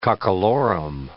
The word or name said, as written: cockalorum